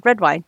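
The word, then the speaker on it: Redwyne: In the back, we have Ryan Redwine, I believe it's pronounced. – Redwine. – Yeah, Ryan Redwine, who's the commander of the Kingsguard, so he's on duty.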